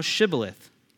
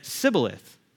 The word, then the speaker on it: Shibboleth: If he said no, they would say to him, say now Shibboleth. But he said Sibboleth, for he cannot pronounce it correctly.